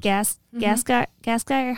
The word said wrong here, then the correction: Gasteyer: How do you say her last name? – Anna Gasteyer? – Gasteyer.